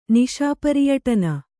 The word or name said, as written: ನಿಶಾಪರಿಯಟನ